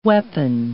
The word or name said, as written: weapon